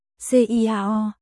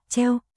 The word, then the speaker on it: Ciao: Ciao, đọc là treo, chào chào trong tiếng Ý có thể dùng để chào hỏi hoặc tạm biệt.